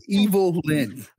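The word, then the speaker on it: Evil-Lyn: You're saying her name wrong, man. – It's Evil Lyn. I didn't say it wrong. It's Alex who's saying.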